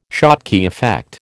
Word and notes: Schottky effect: American English

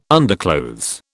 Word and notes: underclothes: British English

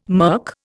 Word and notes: murk: Indian

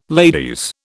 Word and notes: ladies: British English

